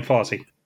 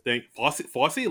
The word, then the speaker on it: Fossey: Diane Fossey. – There it is. Fossey? Like, Fossey Vernon? – Not spelled the same way, but pronounced the same way.